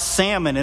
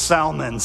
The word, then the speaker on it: Salmon: So I think it's pronounced salmon, so we'll try that. But it's a guy's name, not a fish.